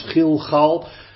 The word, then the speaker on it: Gilgal: Het gaat daar dus altijd om de medeklinkers. G-L, G-L. En dat wordt dan uitgesproken hier als Gilgal.